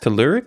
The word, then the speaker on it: Telluric: Is it pronounced Telluric? – Telluric? – Telluric. – Telluric, okay, that's totally what I said.